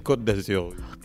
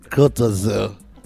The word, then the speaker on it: Côte d'Azur: Côte d'Azur? Côte d'Azur. – Côte d'Azur.